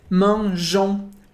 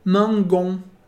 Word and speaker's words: mangeons: A gente mantém esse E no meio, só para que a pronúncia do G fique mangeons. Porque senão seria mangon. E c'est pas ça.